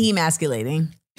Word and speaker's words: emasculating: They're mad at her because, well, they're mad at the cover because they're saying it's demasculating men because, am I saying it right? Demasculating or emasculating? – Emasculating.